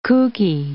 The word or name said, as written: kookie